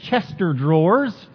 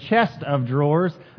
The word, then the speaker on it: chest of drawers: Another common mispronunciation we sometimes say is chester drawers. Rather, it is chest of drawers.